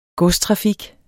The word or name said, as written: godstrafik